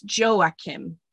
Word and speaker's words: Joachim: So I did look up how to say his name. And I think it's Joachim.